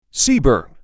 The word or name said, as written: Seaborn